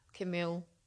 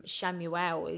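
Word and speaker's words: Chamuel: It depends how we how you pronounce it into where you are. I say Camille, but um, I think in um, Shamuel is what you say in the in the states.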